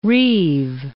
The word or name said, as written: reave